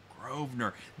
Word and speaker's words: Grosvenor: It's Grosvenor. The S is silent. – Grosvenor.